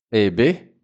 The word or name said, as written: eh beh